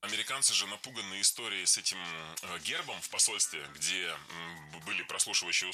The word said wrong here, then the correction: гербом: Не гербом, а гербом. В этом слове при склонении ударение переносится на окончание. Поэтому гербом, гербами, гербах, гербы. Похожие на рассказчиков судьбы семейные фамильные гербы.